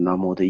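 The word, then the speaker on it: 南无: 无就是那个应该是南方的口音，现在还是把无念成摩，那这样子的话，那这个南摩的意思就是有，所以这是顶礼的意思。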